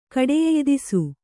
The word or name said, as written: ಕಡೆಯೆಯ್ದಿಸು